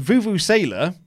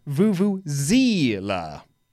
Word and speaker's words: vuvuzela: also says that Vuvuzela, which we've been talking about a lot, is actually pronounced Vuvuzela. The Z is the same as zebra, not the American Z.